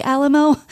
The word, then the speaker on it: Alamo: Now, his name is spelled A-L-A-M-O, and I assumed it was Alamo, like, mm-hmm. Remember the Alamo? But it is not. I've gotten it from many sources. It's pronounced Alamo.